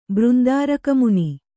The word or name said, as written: ಬೃಂದಾರಕಮುನಿ